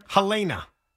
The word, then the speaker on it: Helena: It's not Helena. It's Helena.